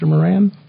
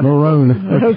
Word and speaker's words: Moran: Is that the correct pronunciation, Mr. Moran? – It's close enough. Marone. – Marone.